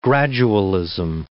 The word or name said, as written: gradualism